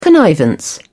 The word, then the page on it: connivance: British English